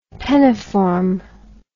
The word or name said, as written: penniform